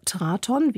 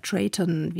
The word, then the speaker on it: Traton: Jetzt also neuer Anlauf für Traton, wie es sich schreibt, oder Traton, wie es im Konzern ausgesprochen wird.